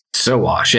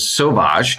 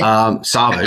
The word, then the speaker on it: Sowash: Sowash, yes. It's actually French. It was Sowash. – Oh, really? – Sowash, yes.